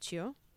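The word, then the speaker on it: qu: It is spelled Q-U with like a long bar over the U, but it's pronounced Chu.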